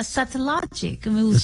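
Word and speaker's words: Satellogic: ¿Cómo se lo pronuncia? Satellogical. – Sí, sí, sí.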